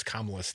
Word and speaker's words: Kamala: And Brian, I believe the other day, also texted in and said it hurts when Crudy pronounces Kamala's name.